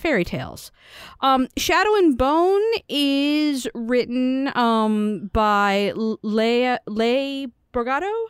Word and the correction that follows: Bardugo: I believe is how you pronounce her last name. – Bardugo.